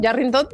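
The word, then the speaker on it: Yárrington: Le dije, sí, tienes toda la razón, Yarrington debe de tener tilde porque la pronunciamos como una palabra esdrújula y la pusimos así y evidentemente recibimos una cantidad de mensajes que no te quiero decir, porque así como, ¿de dónde?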